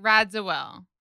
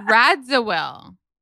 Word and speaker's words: Radziwill: Well, Carol Radziwill is what is happening with you? – How'd you say that last name? – Oh, I said it weird for a second. Radziwill.